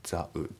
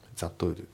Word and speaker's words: that would: で、あと発音ですけど、 that の T がね、抜けますね。 the would。 that would じゃなくて the would。